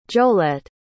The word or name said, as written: jolette